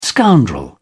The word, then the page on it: scoundrel: British English